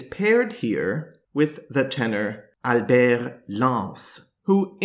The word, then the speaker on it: Albert Lance: changed his name to Albert Lance which, of course, in French is pronounced Albert Lance.